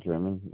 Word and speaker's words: Jerman: My name is Jack German. Last name is spelled J-E-R-M-A-N.